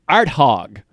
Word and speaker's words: Arthog: He says, you got to go to this town called Arthog. – Arthog. – A-R-T-H-O-G, and it's actually pronounced that way.